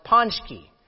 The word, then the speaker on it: pączki: Now, pączki, you know what that, if I spell it, it's a Polish spelling, P-A-C-Z-K-I, but it's pronounced pączki.